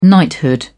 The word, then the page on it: knighthood: British English